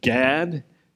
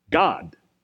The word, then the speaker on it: Gad: Now, in English translations, the name mentioned here that I pronounced Gad is spelled G-A-D, right? But the normal pronunciation for this name in Hebrew is God. But if you say God in English to English speakers, they think you're talking about God, not Gad. So I'll use Gad, so you don't think I'm talking about God.